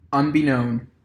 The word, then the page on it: unbeknown: American English